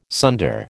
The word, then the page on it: sunder: American English